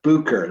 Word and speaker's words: Bucher: Bucher, like boo, like you're scaring someone, and then Kerr.